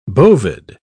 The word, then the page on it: bovid: British English